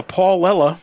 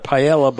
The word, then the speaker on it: paella: Okay, episode 172, Taramusa instead of Taramasu, Lafroyage instead of Lafroig, Paulella instead of Paella by Gusto Eroa instead of Gusto Eroa, Gelato instead of Gelato.